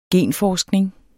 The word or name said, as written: genforskning